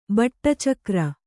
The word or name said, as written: ಬಟ್ಟಚಕ್ರ